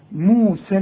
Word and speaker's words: موسى: الله، طب ما هي موسى فيها يه، واليه بتتحول إلى ألف في النطق. موسى، أنا لغيت اليه لما وصلت قلت موسى الكتاب، الحكم ده هتاخده فيما بعد، لكن إذا وصلت إلغي الياء مؤقتا. موسى الكتاب، وكأن الياء غير موجودة.